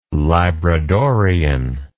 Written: labradorean

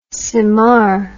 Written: symar

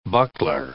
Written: buckler

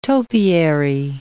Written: topiary

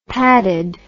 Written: padded

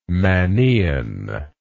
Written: mannaean

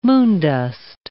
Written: moondust

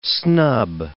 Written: snub

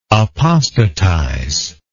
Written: apostatism

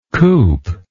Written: coupe

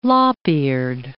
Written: lop-eared

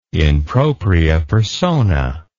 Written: in propria persona

